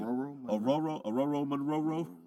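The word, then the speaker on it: Ororo Munroe: Aurora Monroe.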